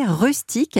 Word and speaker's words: Rustik: Vous avez pour projet de lancer Rustique. Alors, je le précise, ça s'écrit R-U-S-T-I-K.